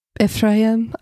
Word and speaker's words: Ephraim: You have to say it like Ephraim. I can't roll my tongue.